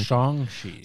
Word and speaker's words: Shang-Chi: I said that wrong. Shang-Chi, not Shang-Chi. – Shang-Chi.